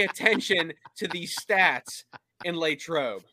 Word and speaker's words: Latrobe: But on the pronunciation of Latrobe. – Oh, boy. – It's Latrobe.